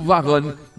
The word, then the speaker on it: Varane: do Egito e Liverpool; e o Varane, da França e Real Madrid.